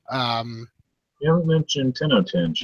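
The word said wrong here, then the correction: Tenjho Tenge: yet. – You mean Tenjo Tenge? – Tenjo Tenge, however you pronounce it.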